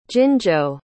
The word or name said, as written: jinzhou